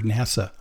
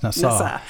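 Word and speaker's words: Nassau (נשא): This word lift is one of the few I remember in Hebrew, because it's the word NASA. I used to work for NASA. but it's actually pronounced Nassau.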